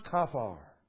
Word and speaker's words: kaphar: It is a word that's pronounced kaphar.